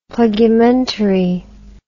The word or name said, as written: plugumentary